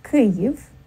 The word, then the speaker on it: Kyiv: So the proper pronunciation would be Kyiv, and that's the Ukrainian pronunciation. It coincides with the Ukrainian transliteration into English, which would be K-Y-I-V, rather than the more common one, but now obsolete, K-I-E-V. So to say Kyiv, you need to think of maybe the first syllable and the second syllable as slightly separate.